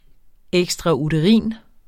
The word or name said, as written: ekstrauterin